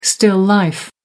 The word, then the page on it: still life: American English